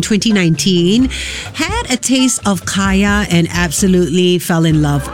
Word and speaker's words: Kaya: Now, apparently Kaya is a common girl's name in Scandinavia, and when written with a Y, like K-A-Y-A, it's pronounced the same in both English and Norwegian.